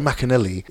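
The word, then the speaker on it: Maccarinelli: But Roy Jones Jr. got knocked out by Enzo Macarelli. I've said his surname wrong. But Enzo Macanelli is Joe Calzaghe's cousin.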